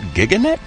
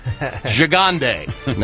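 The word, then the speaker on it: Gigandet: I was like, is it Giganet? No! Gigande. Now I know.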